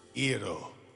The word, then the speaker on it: gyro: Gyro.